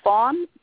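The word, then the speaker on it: Phon: This is Fawn from Virginia. – What's your first name? – Fawn, pronounced like a little baby deer.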